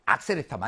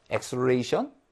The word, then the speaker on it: acceleration: 액셀레타 많이 들어보지 않았어요? 네? 액셀레타? 예. 네. 근데 그 발음이 잘못된 거죠? 콩글리시죠? 액셀레이션?